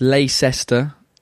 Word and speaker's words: Leicester: It's the same as Leicester. It's Leicester, literally written.